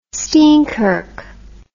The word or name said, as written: steinkirk